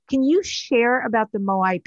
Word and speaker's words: Moai: And I hope I'm going to say this right. The Moai people. Is that how you say it? – Moai.